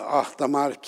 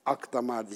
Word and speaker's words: Ahdamar: Ahdamar Kilisesi ki halk tarafından Akdamar diye telaffuz edilir ama aslı Ahdamar'dır. Nitekim o şekilde sitesi filan da şu anda ciddi anlamda restore edildi ee, ve buna benzer faaliyetlerine devam ediyor.